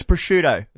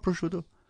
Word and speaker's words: prosciutto: It was prosciutto. – Yeah. – No, it's prosciutto. – Yeah. – Not prosciutto.